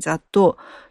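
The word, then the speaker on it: 雑豆: さらにさらに調べると、我が国の農林水産省の作物統計資料には、大豆、落花生以外の豆類の総称を雑踏ですね、雑なる豆と書いて雑踏と言って、見た目も味も多彩で種類も豊富ですと書いてありました。ですから、大豆と落花生は独立して単独でこう分類されていて、残りが雑踏、小豆、エンドウ豆、その他の豆類は雑踏というわけですね。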